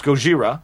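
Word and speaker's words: Gojira (ゴジラ): It's actually pronounced in Japanese. I believe it's pronounced Gojira. – Gojira.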